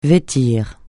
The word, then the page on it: vêtir: francais